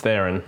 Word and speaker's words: Theron: It's Theron.